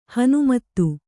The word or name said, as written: ಹನುಮತ್ತು